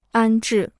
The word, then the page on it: 安置: ān zhì